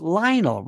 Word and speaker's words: Lionel: I said, it's Lionel. Rhymes with vinyl.